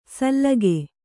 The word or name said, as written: ಸಲ್ಲಗೆ